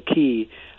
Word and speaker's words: qi: It's called the qi or the qi. It's spelled as C-H-I in Chinese.